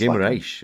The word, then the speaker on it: Guimarães: Even John's probably correct pronunciation of Guimarães, when I was going to say Guimarães. – Guimarães is, yeah. He's John's right, just the commentator.